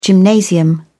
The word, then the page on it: gymnasium: British English